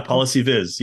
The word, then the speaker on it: PolicyViz: Do you pronounce it Policy Viz? – Yeah, Policy Viz. Yeah, maybe a little more American English than British English.